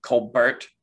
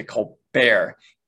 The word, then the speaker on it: Colbert: He changed the way he pronounced his last name. So his brother actually uses the original way of pronouncing the name, which is more like Colbert. And so he changed it to Colbert.